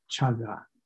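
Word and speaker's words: cāga: But there's a Pali word, chaga, and if you're interested in Pali, it's spelled C-A-G-A, chaga.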